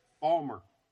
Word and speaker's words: Baltimore: It's Baltimore. Wait a minute. – Yeah. – No. Say it correctly. Balmer.